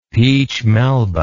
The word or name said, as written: peche melba